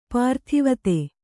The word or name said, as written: ಪರದೇವತೆ